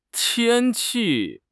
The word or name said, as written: tian1 qi4